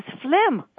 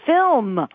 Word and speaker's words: film: I've repeatedly taught him the correct pronunciation. I tell him that it's film.